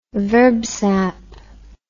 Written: verb sap

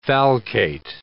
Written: falcate

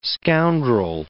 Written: scoundrel

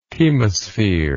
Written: chemosphere

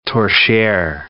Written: torchiere

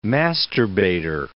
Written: masturbator

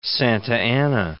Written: Santa Anna

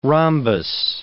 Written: rhombus

